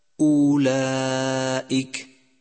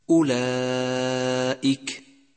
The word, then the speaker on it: أولئك: مثال: أولئك، إن، والصواب أولئك، إن.